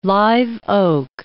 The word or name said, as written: live oak